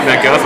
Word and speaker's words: MacGuff: Bryan and Bryan, thank you for taking a break from production at Illumination MacGuff. Is that how you pronounce it? – Yeah. – MacGuff.